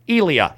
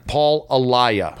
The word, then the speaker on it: Alaya: How would you like to pronounce your name? – Alaya works. Alaya. – Paul Alaya. – Paul Alaya. – And I said Paul Elia. – Elia. – That's completely off.